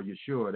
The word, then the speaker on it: Yeshua: So uh, serving Jesus or Yeshua, that's his, uh, how you pronounce his name in Hebrew.